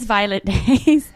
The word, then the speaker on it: Violet Days: Her name is Violet Days.